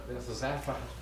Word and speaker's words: Bethesda: Bethesda. – Yeah, Bethesda.